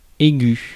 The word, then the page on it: keen: /ˈkiːn/